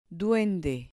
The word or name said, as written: duende